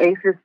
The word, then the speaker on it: ACEs: And I remember during your presentation, you went into that when you was talking about ASA. Is that how you could pronounce it? It's called ACEs? – Yep, the ACEs study.